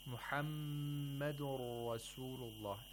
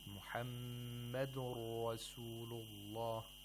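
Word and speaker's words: مُحَمَّدٌ رَسُولُ اللَّهِ: Muhammadun Rasulullah. And then we have idgham of nun sakina into ra. Okay, so the nun sakina is not pronounced. Instead, the ra is pronounced with tashdeed. Muhammadun Rasulullah.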